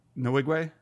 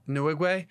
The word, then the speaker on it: Nwigwe: Tobe Nwigwe. Nwigwe um, he has this track called "I Choose You."